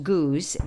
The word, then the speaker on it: Goudge: In Britain, actually, her name is pronounced Goose, but we British and Americans don't always agree on pronunciation. So her name is spelled G-O-U-D-G-E. So I pronounce it the way it's spelled, but I believe it should be pronounced Goose.